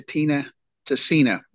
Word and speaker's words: Tina Tessina: Well, Dr. Tina Tessina, am I saying it right? – You're saying it exactly right.